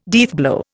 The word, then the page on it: deathblow: Indian